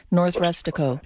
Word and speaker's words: North Rustico: Is it North Rustico? – Hmm. R U S T I C O, North Rustico.